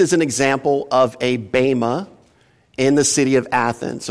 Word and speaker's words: bema: You'll hear it referred to as bema or bema. Bema is actually the proper pronunciation, but who cares, right? It's all Greek anyway. So it's bema or bema, either one of those will work. And I many times go back and forth, but the proper pronunciation is bema.